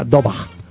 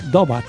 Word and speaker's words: Daubach: Mira cómo está, se escribe Brian Daubach pero se suena do, doba. Tú lo tiras doba más o menos.